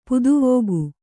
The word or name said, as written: ಪುದುವೋಗು